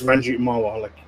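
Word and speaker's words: Ranjit Marwa: You actually said it right. When you reviewed the Exile teaser, it's Ranjit Mara.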